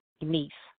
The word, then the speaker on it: Deniece: It's spelled D-E-N-I-E-C-E, Denise, but it's with the D-E in front of Nice.